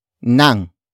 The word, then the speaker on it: nang: The answer is introduced by the complement phrase, starting with nang.